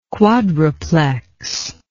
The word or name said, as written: quadruplex